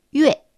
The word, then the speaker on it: 月: 月という字は、ゆえと発音します。